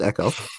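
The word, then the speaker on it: Ekow: letting us know the correct pronunciation on Echo. Yeah, it looks like something else, but it's just Echo.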